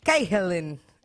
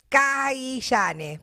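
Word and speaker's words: Cahillane: Voy a hablar de Steven Cahillan. así se pronuncia. Se escribe Kailane, pero se pronuncia Cahillan.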